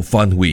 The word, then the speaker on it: Phan Huy: And have you heard of Vietnamese fashion designer Phan Huy? P-H-A-N-H-U-Y for Phan Huy. Yes, you pronounce the name as Phan Huy.